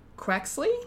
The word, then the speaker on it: Quaxly: and Quaxly.